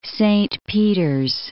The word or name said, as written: Saint Peters